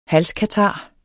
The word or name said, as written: halskatar